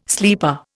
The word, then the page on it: sleeper: Indian